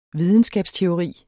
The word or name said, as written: VIDENSKABSTEORI